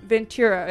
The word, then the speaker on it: Ventura: Ventura.